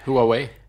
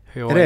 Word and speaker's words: Huawei: Huawei. – Huawei.